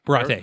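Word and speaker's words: Baratie: And she's, you know, super snooty about eating at the Birate? – Birate, yeah, is the name of the restaurant. – I just couldn't remember how to pronounce it.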